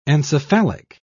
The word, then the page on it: encephalic: British English